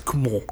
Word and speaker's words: Cumont: So Franz Cumont. – Yes. – Did I pronounce that correctly? – Yes, Cumont. – Cumont, yeah.